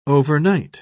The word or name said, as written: overnight